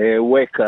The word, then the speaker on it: וויקה: טוב, בוא למד אותנו מעט על הציפור הזו שעדיין לא הצלחתי להבין איך מבטאים את השם שלה. וויקה או וויקה? – וויקה. – כן, זהו, תיארתי לעצמי שזה וויו. אוקיי. – כן, זה וויקה.